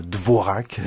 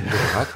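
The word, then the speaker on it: Dvořák: Avec, on le rappelle aussi, une orthographe qui diffère un peu de la prononciation. Souvent, on voit Dvorak. – Dvorak, voilà. En réalité, il y a des accents circonflexes sur le A et sur le R.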